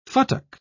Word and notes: futtock: British English

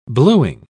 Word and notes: bluing: British English

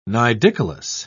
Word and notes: nidicolous: British English

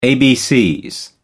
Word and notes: abcs: British English

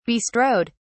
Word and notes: bestrode: British English

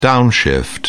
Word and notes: downshift: British English

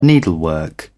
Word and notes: needlework: British English

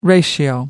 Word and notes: ratio: American English

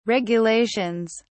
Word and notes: regulations: British English

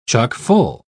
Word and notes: chuck-full: British English

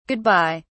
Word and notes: good bye: British English

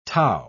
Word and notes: tau: British English

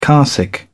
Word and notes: carsick: British English

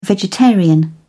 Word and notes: vegetarian: British English

